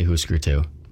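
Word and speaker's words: Hüsker Dü: And I always feel stupid saying that name because it's spelt Husker, but it's got the, like, the Scandinavian double dots over the U. So I hear other people say Husker, and I want to sound cool and fit in with all the cool punk guys. So I say Husker too.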